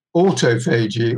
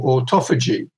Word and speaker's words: autophagy: And one of the most important things that go on that play a role in both um, diabetes and in cancer is something called autophagy or autophagy, which um, uh, autophagy is the American pronunciation.